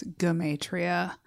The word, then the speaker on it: Gematria: I said Germatria. I think it's Gematria. Got germs on the brain.